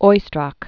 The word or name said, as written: Oistrakh